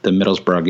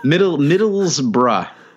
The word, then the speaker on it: Middlesbrough: Baines actually looked pretty good from an attacking perspective in the Middlesbrough game as well. – Excellent pronunciation of Middlesbrough, by the way. – I tried. Yeah. Middlesbrough, I think, right? – It's good.